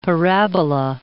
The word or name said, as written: parabola